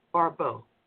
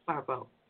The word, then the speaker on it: Barbot: Her first name is spelled, it's really weird, O-X-I-R-I-S, Oxiris Barbeau, B-A-R-B-O-T, Dr. Oxiris Barbeau. – Barbeau, she's the health commissioner.